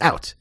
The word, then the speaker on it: UIT: Okay, so what he says, Jonas pronunciation guide, UIT is pronounced out, out.